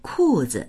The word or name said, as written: ku4zi